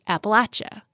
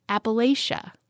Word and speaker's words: Appalachia: And as you may know, if you've been listening to Inside Appalachia for a while, here on our show, we pronounce it Appalachia. But there are other pronunciations that are also accepted. Depending on where you live, the pronunciation changes. In fact, there are at least 6 pronunciations. When you go north of the Mason-Dixon line, for example, most people say Appalachia. Though down in North Carolina, most people cringe if you say it that way.